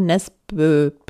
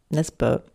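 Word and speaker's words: Nesbø: Es ist von Jo Nesbe. Nespe, das durchgestrichene O.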